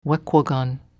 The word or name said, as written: Wequogon